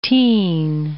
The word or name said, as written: teen